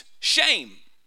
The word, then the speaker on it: shem: The word name in the King James comes from the Hebrew word S-H-E-M, pronounced shame, actually.